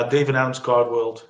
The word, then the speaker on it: Dave & Adams Card World: Spreek je dat zo uit? – Ja, Dave & Adams Card World.